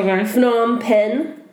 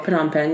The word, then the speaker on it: Phnom Penh: Phnom Penh, Cambodia. – Oh, Phnom Penh, yeah. – Phnom Penh.